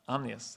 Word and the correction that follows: ominous: How do you pronounce it? Ominous. ominous. Thank you.